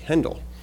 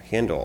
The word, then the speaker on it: Handel: The man was George Frederick, and I looked up the pronunciation of his word and said it's actually Handel. I've all pronounced it Handel all these years incorrectly, apparently.